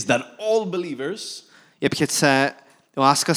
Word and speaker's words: Láska: Láska.